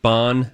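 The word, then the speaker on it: bánh: Bon min bun and bon min bowl. Bon is spelled B-A-N-H, okay? – Oh.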